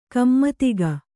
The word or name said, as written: ಕುಮುದಿಕೆ